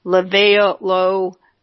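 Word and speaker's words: Livalo: Lipitor, Levato, no, I'm sorry, I pronounced that wrong. Levalo, Mebicor, Altacor, Zocor, Prevacol, Lescol, and Crestor.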